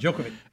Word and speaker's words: Djokovic: Is it Djokovic? Did I say that right? Djokovic.